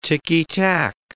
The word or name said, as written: ticky-tack